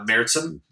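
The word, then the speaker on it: Märzen: Like, you're saying Marzen, M-A-R-Z-E-N, with the two things over the A. That's how you say it? – Yeah, Meritzen.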